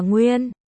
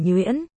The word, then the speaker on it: Nguyên: Còn nhắc nhở, chữ Nguyên đọc là Nguyên. Không phải Nhuyễn.